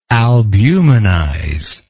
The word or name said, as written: albumenize